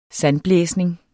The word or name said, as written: sandblæsning